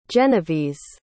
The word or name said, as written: genovese